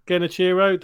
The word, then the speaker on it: Genichiro: Is it Genichiro in Sekiro? – Genichiro, the one on top of the castle.